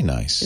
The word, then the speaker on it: gneiss: Much of the schist is very nice. – Is that how you pronounce that word? – S-C-H-G-N-E-I-S-S. – Nice. – Oh, nice. Yeah, it is. Some Schist is G-N-E-I-S-S. Nice.